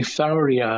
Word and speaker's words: Fauriei: the variety Faurii, I guess that would be how you pronounce it, F-A-U-R-I-E-I.